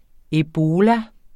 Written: ebola

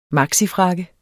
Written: maxifrakke

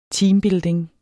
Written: teambuilding

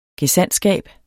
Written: gesandtskab

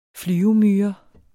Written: flyvemyre

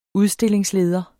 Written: udstillingsleder

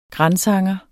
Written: gransanger

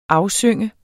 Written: AFSYNGER

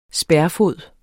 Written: spærfod

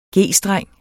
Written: G-streng

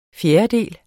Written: fjerdedel

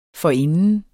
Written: forinden

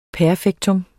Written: perfektum

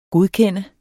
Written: godkende

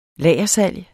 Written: lagersalg

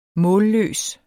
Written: målløs

